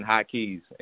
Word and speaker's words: Hykeyzz: But if anybody wants to find me, only thing you have to do is type in High Keys, and it's simple. It's spelled HYKEYZZ.